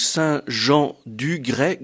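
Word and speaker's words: Saint-Jean-du-Grais: Écoutez, le plus simple, c'est que nous avons un site internet dont l'adresse est Saint-Jean-du-Gré, donc Saint-Jean-du-Gré, Gré, cela s'écrit G-R-A-I-S, tout attaché sans espace, sans rien, .com.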